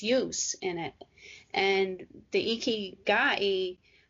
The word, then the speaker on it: ikigai: It's pronounced ikigai. It's spelled I-K-I-G-A-I.